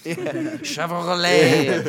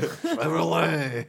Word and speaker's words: Chevrolet: Chevrolet. – Chevrolet.